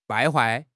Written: 败坏

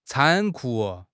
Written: 残酷

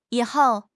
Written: yi3 hou4

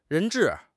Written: 人质

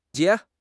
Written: xie3

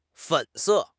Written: 粉色

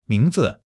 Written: ming2 zi5

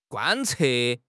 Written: 贯彻

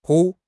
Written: 铺

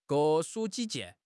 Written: 各抒己见

